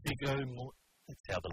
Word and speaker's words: Viggo Mortensen: Viggo Mortensen.